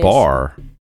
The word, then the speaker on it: Barre: Word is bar, because it's spelled Barre.